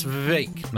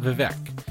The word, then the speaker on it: Vivek: That Vivek Ramaswamy, and I know it's Vivek, not Vivek. The only reason I know that is because it was explained to me it rhymes with fake.